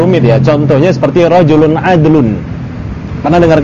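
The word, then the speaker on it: adlun (عدل): Dan adlun bukan adil. Ya, adlun bukan adil. Adlun masdar. Namun bimakna sifat. Ya kan? Jadi kita enggak bacanya dengan bacaan rajulun adlin. Ya, rajulun adlin, tapi rajulun adlun.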